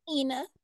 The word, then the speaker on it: Ina: Ani, Aini, I can never pronounce her name. – Ina. – Ania, see? – Ina. – Ina. – Yeah, three letters. Ina.